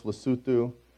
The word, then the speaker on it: Lesotho: For the non-Africans in the room, it's Lesotho is how we would, if you saw it spelled. But pronounced Lesotho.